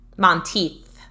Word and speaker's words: Monteith: Monteith, like teeth.